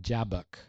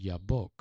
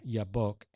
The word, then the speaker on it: Jabbok: Finally, and he came to, I always called it Jabbok, J-A-B-B-O-K, but in Hebrew it's pronounced Yabbok. He came to a place, a stream, Yabbok, and he wrestled with an angel.